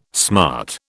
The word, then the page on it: smart: British English